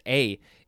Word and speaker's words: aye: Next, we have this would be A, pronounced A. It is spelled A-Y-E, or just A-Y.